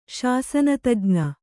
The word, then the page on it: ಶಾಸನತಜ್ಞೆ: śasana tajñe